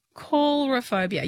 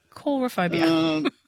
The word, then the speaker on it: coulrophobia: Well, C-O-U-L. I'm saying coulrophobia. Yeah, coulrophobia. – Yeah, like cover on air. Yeah, cool. Yeah, okay. Cool. – Yeah, coulrophobia. Yeah, I'm saying it right.